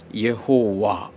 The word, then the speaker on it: യാഹ്വേ: യഹോവ എന്ന രീതിയിൽ പറയാൻ തുടങ്ങി എന്നാൽ പലരുടെ അഭിപ്രായം അങ്ങനെ പറയുന്നതിനേക്കാൾ കറക്റ്റ് ഉച്ചാരണം യാഹ്വേ എന്നാണെന്നാണ്